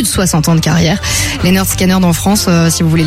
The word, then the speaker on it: Lynyrd Skynyrd: Oui, parce que ça s'écrit avec un Y, mais sur leur premier album, l'album s'appelle Pronounced Lynyrd Skynyrd. – Ouais, c'est vrai. Donc sans doute c'est comme ça qu'il faut dire.